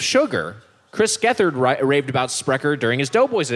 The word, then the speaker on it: Sprecher: Originally started as a brewery in Milwaukee in the early 1980s, Sprecher, pronounced Sprecher, did it right,